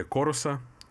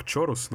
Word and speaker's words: chorus: Также есть педали коруса. Многие видели, да? Кто-то может читать это как чорус, но это корус, правильно читается.